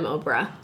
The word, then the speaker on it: Oprah: Anyway, somebody found out on her Wikipedia page that she was born Orpa, but everybody pronounced and switched the P and R. And they mispronounce it so much that she's just like, fine, I'm Oprah.